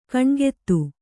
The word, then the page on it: ಕಣ್ಗೆತ್ತು: kaṇgettu